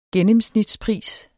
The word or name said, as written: gennemsnitspris